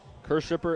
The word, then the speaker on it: Kirschsieper: Thank goodness for the Jupiter pronunciation guide at the bottom of the roster. And Kershipper, it would have taken a pretty good guess to get that pronunciation from that spelling. K-I-R-S-C-H-S-I-E-P-E-R.